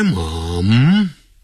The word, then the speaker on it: mom: 好，一开头 ,my mom, 就是念 mom, 英国人呢会念成 m-u-m, mum, mum, 发呃的音，肚子呃的呃， mum, my mom is eating breakfast over there. 好，我妈妈在那边正在吃早餐，可是老美他们就会念成 Mom, M-O-M.